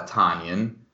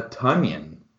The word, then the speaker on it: Tonyan: Robert is actually not a Tonyan, but he's a Tunyan, as in Funyan, or Onion, as in rhymes with John Runyon.